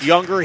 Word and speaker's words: Yuengr: And this is the Younger Gymnasium, Steve Younger, Y-U-E-N-G-R, pronounced Younger.